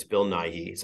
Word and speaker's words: Nighy: I mean, I think that look, this is the trickiest race of the night, and you did it's it's Bill Nighy. So I think you kind of said it correctly.